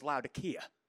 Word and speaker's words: Laodicea: And technically in the Greek, the C is a hard C, so it's Laodicea.